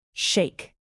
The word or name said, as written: shake